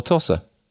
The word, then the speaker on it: Tosa: Kind of, Cheshire's Tosa. Is it Rossa or Tosa? – T-O-S-A, Tosa.